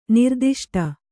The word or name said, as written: ನಿರ್ದುಷ್ಟ